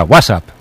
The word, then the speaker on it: WhatsApp: No, peor aún, con acento en la primera. WhatsApp, ¿no? – Un WhatsApp.